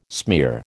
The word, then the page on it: smear: American English